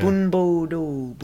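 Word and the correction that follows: bunbu ryōdō: No, bunbu ryo do.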